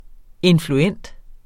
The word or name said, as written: influent